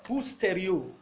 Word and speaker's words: hustereo: Now, the word for come short is the word to, okay, osterio. It means to come behind, or to lack, or to be inferior concerning something. It's spelled H-U, okay, who? Hustereo, that is better, better pronounced. H-U-S-T-E-R-E-O. H-U-S-T-E-R-E-O.